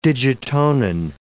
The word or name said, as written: digitonin